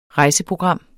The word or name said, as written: rejseprogram